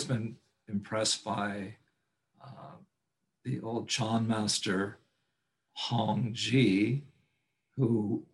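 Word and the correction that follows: Hongzhi: Guagu has corrected me. It's pronounced Hungja, Hungja.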